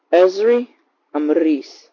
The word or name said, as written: Ezri Ambriz